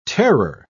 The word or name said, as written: terror